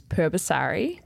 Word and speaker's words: Purbasari: When people ask me how to pronounce my last name, I ask them if they want the Western pronunciation or the Indonesian pronunciation, because most people cannot pronounce the Indonesian pronunciation, so I give them the Western version. – Challenge accepted. – Okay, so the Western version is Purbasari.